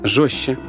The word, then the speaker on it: жёстче: Сейчас в русском языке масса буквенных сочетаний, которые произносятся как «ща». Например, «СЧ» – «счастье», «СТЧ» – «жёстче», «ЗЧ» – «резче», «ЗДЧ» – «объездчик», а также «ШЧ» – «веснушчатый» и «ЖЧ» – «мужчина».